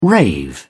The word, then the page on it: rave: British English